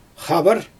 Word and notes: xabar: Xyzyl

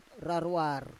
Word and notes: rarwar: Roon

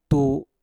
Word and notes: tu: mpur

